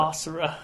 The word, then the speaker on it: Asura: How come you can't pronounce it right? Asura. – Asura. – Asura. – Asura. – It's Asura.